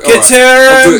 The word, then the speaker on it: ceterum: I'm just trying to tell you to say it properly. Ceterum.